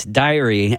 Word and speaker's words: Diary: And the band is called Diary. And I said Dairy. And almost simultaneously, Brady, who assists me every Saturday, Brady was like, hey, actually, it's Diary.